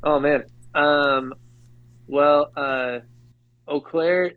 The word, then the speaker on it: Eau Claire: So I've never been to Boo or Claire. – Did I pronounce that right? Eau Claire, like O. – Eau Claire. Okay. Oh yeah, yeah. It's like French.